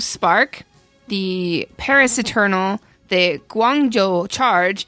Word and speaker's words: Guangzhou: Hangzhou, Guangzhou, Chengdu.